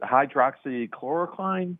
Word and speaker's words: hydroxychloroquine: One of the other controversies I'd like to ask our guests about is around the is it hydroxychloroquine?